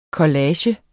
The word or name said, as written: collage